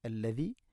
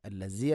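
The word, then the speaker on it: അല്ലദി (الذي): വല്ലാഹു അല്ലദീ ഹുവ അല്ലാഹു അല്ലദി അല്ലദി, ദാലിൻ്റെ ഉച്ചാരണം ശ്രദ്ധിക്കുക. അല്ലസി അല്ല അല്ലദി.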